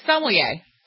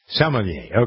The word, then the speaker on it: sommelier: She and her father are both, how do you actually pronounce it? I always tease you because I say sommelier. I know, you always do. Sommelier. – Sommelier, okay.